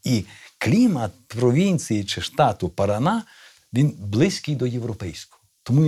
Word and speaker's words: Парана: Ну, е, правильно буде наголос на останньому складі, буде штат Парана.